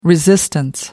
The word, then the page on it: resistance: American English